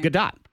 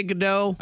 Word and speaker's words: Gadot: How do you say her name? – Gadot. – Gadot. – Gal Gadot. – Yeah, some people say it differently. – Yeah, because it looks, you want to say Godot.